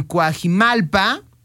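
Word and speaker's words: Cuajimalpa: Ninel Conde estuvo presente justamente en una alcaldía allá en Coajimalpa.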